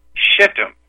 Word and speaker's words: Shittim: S-H-I-T-T-U-M. Pronounced shittim. So I did not say S-H-I-T. I said a word that is in the Bible.